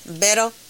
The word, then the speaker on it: Beto: Beto? Is that how you say it? Beto? – Yep.